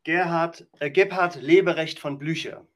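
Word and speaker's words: Gebhard Leberecht von Blücher: So he, Blucher, or you want to pronounce his first names for me? I believe it's Gerhard Lieberecht von Blucher. – Gerhard Lieberecht von Blucher. – Thank you very much.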